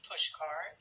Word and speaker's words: Puszkar: My name is Jane Puszkar. It's J-A-N-E, and the last name is spelled P as in Patrick, U, S as in Samuel, Z as in Zebra, K-A-R.